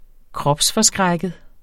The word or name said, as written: kropsforskrækket